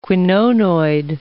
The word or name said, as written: quinonoid